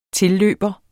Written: tilløber

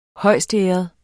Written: højstæret

